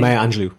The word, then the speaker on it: Angelou: I remember hearing that from um, Maya Maya Angela. Maya, do I pronounce her name? Maya Angelou. Maya Angelou.